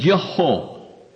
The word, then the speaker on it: Yehovah: A great many of our Hebrew names begin with Yehovah, as we pronounce it,